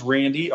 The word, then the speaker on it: Randy: Well, my name is Randy, R-A-N-D-Y, and my last name is spelled S-E-E-D-O-R-F-F.